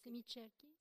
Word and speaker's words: semicerchi: Or, les demi-cercles en italien se disent, c'est là que je vais avoir du mal, semicerchi, semicerchi ou cerchi. Mais semicerchi, non, je ne sais pas le prononcer, donc il faut que. – Semicerchi.